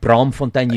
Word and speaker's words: Braamfontein: You're like Braamfontein.